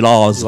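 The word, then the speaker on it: La's: Ce nom correspond, le nom Laz, en fait, c'est le terme lads, qui veut dire les gars, mais avec l'accent de Liverpool, ça fait Laz. Voilà. Prononcé donc avec cet accent de Liverpool.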